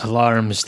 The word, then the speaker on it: alarums: It's alarums, thank you very much. Alarums.